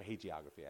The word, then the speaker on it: hagiography: Or hagiography, actually. I think it's a hard G.